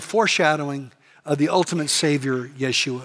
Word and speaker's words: Yeshua: Because in the Hebrew, Joshua is pronounced, get this, Yeshua, Yeshua. Does that sound familiar to anybody? That is actually Jesus in Hebrew, Yeshua.